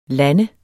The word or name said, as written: land